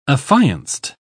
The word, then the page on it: affianced: British English